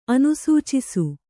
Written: ಅನುಸೂಚಿಸು

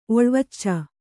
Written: ಒಳ್ವಚ್ಚ

